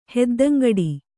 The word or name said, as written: ಹೆದ್ದಂಗಡಿ